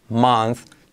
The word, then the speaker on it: month: Pero May is the easiest month to pronounce in English. May is the easiest, easy, el más fácil, easiest month to pronounce in English. Cuidado, repetimos month con sonido TH, ¿vale? Como Z.